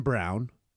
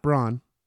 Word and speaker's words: Braun: they get Christian Brown, not Braun.